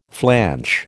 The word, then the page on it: flanch: American English